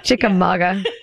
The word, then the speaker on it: Chickamauga: Did I say that right? – Chickamauga. Yeah. It's a Cherokee word.